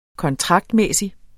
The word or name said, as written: kontraktmæssig